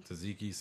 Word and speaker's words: tzatziki: Tzatziki sauce was everywhere, and I'm like trying to mop the stuff because you know I don't do condiments.